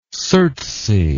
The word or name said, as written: surtsey